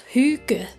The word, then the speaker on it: hygge: It's pronounced hygge.